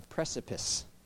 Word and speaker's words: precipice: You got to get around some people that aren't afraid of stepping over a prefacist. I still said it wrong. A precipice.